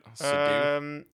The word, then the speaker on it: Seydoux: Is it Seydoux? Is that how you say it? – Seydoux? Yeah, say it that way.